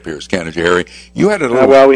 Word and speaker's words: Canajoharie: It's Canajoharie.